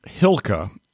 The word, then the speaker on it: Chylka: And it's a series called Hylka. Now, it's spelled Chylka.